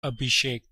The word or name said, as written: Abhishek